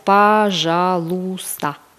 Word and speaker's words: пожалуйста: On le prononce pajalusta. Alors, le mot pajalusta, c'est un mot génial en russe parce que vous pouvez l'utiliser quand vous dites s'il vous plaît pour acheter quelque chose.